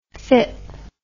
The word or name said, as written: fip